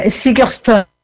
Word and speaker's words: Sigurdsson: On a Elliot Grosse-Hove, on a Jacob Lohmann, ce sont des Danois, on a Ingvar Eggert, Sigurdsson, alors je crois qu'on prononce Sigurdsson normalement, c'est un clan. – Sigurdsson. – Ouais, Sigurdsson, bah oui.